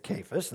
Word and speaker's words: Cephas: So we see in verse 1 here, or verse 11, when Cephas, in fact, I looked that up finally in the Greek, how to pronounce that. It's driving me crazy. It's Cephas, and it's Peter.